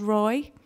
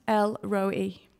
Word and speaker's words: El Roi: God is not called Roy. Nothing against anybody called Roy. If there's a Roy in here, I'm sorry. It's nothing against you. But Hagar did not name God Roy. It's pronounced El Roi.